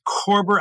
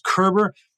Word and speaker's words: Körber: He's Craig Moore with Corber. Actually, it's pronounced Kerber. Kind of looks like it's spelled Corber.